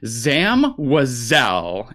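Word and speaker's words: Zam Wesell: The bounty hunter who tried to kill Padme in episode 2, how do you pronounce her name? – Zam Wazell, and I will die on that hill.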